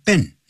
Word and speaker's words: been: be 动词 been 要念成 been 那是短元音 it been 而不是 been I have been in this city for 20 years 我在这个城市待了 20 年了或者 I've been living in this city I have been living in this city for 20 years.